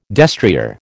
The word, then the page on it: destrier: American English